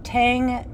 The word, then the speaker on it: Tang: And it's a whole thing with Chinese pronunciation. Mm. So, Tang is kind of what it's become.